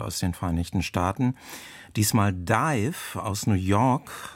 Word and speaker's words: DIIV: Zu Anfang ihrer Karriere schrieben sie sich mal, wie man es englisch ausspricht, also D-I-V-E, Dive. Wegen Verwechslung mit einer belgischen Band gleichen Namens schrieben sie sich dann anders, D-I-I-V, in großen Buchstaben ausgesprochen, aber nach wie vor Dive.